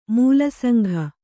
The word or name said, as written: ಮೂಲಸಂಘ